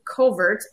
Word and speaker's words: covert: It's spelled covert, but pronounced covert, which is a English term that describes a dense thicket of cover for wildlife.